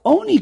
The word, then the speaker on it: oni: Rabbi Yossi Aglili responds to that, mikarinan ani, yeah, but do we read it ani? Do we say lechem ani? No, oni karinan. We read it as oni.